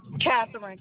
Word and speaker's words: Katherine: Katherine, K-A-T-H-E-R-I-N-E. – K-A, spell it. – K-A-T-H-E-R-I-N-E. – K-A-T-H-E-R-I-N-E.